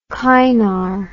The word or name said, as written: Kynar